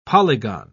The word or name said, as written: polygon